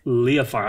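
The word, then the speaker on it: Leafar: That's Leofar. I looked it up. That's how it says I'm supposed to pronounce it.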